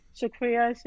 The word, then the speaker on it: Shukriya: You're pronouncing my name perfectly, much better than other Americans who's trying to say Shukriya, Shakira. But yeah, thank you very much.